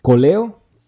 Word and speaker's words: Koleo: Hay una que se llama Coleo, ¿verdad? Que se escribe K-O-L-E-O.